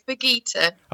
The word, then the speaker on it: Birgitta: Uh, no, no, it's Begita.